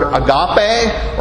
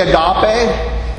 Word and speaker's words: agape: In this instance, it's the word agape or agape, depending on how you think the emphasis should go on the syllables. But I say agape.